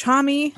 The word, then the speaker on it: Homme: We are very big fans of Josh Homme. – Oh. – And yes, that is how you say his last name. Do not come for us.